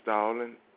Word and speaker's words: Stalling: Stalling, S-T-A. L-L-I-N-G.